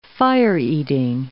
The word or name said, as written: fire-eating